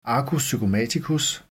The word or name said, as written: arcus zygomaticus